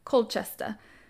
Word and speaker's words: Colchester: Yeah, which today is Calchester. I looked up that pronunciation as well. I know it's wrong. I know I'm saying it wrong. That's okay. – Yeah, Colchester? – Yeah. Colchester. So she was born, they think, around 30 AD.